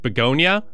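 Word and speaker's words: Bugonia: The second story that I just saw is this movie called, I think it's pronounced Begonia.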